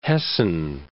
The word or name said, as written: Hessen